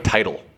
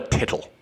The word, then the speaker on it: tittle: It's going to be called like title or teetle. It won't be called a tittle. – Tittle. There's no way. Pronunciation. Let's see how you say it. It's title. – Nice one. – Well, it's got two T's. It's how I would pronounce it. – T-I-T-L. – T-I-T-T. T-I-T-T-L-E is how it's spelled. Come on, bitch. Say it. – Tittle. – Huh. Yeah, that's definitely tittle.